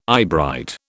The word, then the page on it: eyebright: British English